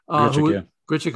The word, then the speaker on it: Grichuk: When Dante Bichette joins us in a few minutes, we'll talk about Randall Grichuk. How do you say that right? – Grichuk, who was in Toronto, where Dante is very familiar with what's going on in Toronto.